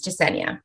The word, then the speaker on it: Jesenia: Is it, just so I don't embarrass myself, and I'm probably going to embarrass myself just by asking this question, is it Jesenia or is it a soft J or a hard J? – No, the first way you said it is exactly how it is, Jesenia.